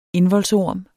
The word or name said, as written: indvoldsorm